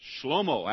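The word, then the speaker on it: Shlomo (שלמה): Shlomo, actually, that's the way you pronounce it in Hebrew.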